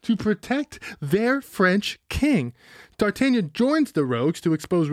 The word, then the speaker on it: d'Artagnan: D'Artagnan. That's how you say it in French.